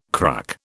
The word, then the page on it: cruck: British English